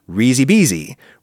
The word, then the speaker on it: risi e bisi: If you elide the E sound at the end of risi into the word E that precedes bisi, then the pronunciation becomes risi bisi, which is delightful.